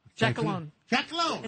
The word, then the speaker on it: Giacalone: Giacalone. It's Italian.